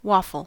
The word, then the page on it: waffle: American English